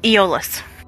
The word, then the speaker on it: Aeolus: Correct me if I'm wrong. – Aeolus. – Aeolus, sorry, I always mispronounce that.